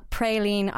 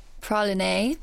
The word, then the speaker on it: Praline: So you're in Ireland to launch the new Magnum Praline ice cream. I don't know if I said that right. – It's Praline.